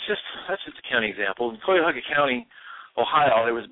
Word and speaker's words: Cuyahoga: Oh, Cuyahoga.